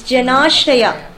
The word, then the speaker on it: Janashraya: It's Janashraya. And when there is a hyphen on top, it's like sh, not s, right?